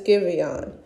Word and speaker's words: Giveon: And his name is spelled G-I-V-E-O-N. Giveon. I'm hoping I'm saying it right, but I think it's Giveon. G-I-V-E-O-N.